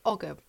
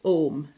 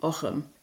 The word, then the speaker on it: Ogham: The pronunciation that's most common in Ireland, I would say, is Ogham, so that's like the modern Irish pronunciation. But for people who are medievalists, a sort of medieval pronunciation or early medieval pronunciation would be Ogham. So those are the two authentic pronunciations that are acceptable.